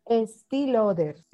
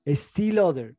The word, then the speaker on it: Estée Lauder: Yo no sé cómo se dice esta empresa, Estée Lauder, ¿cómo es la empresa de cosméticos? – Sí, Estée Lauder. – Estée Lauder, ok.